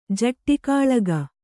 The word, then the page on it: ಜಟ್ಟಿಕಾಳಗ: jaṭṭi kāḷaga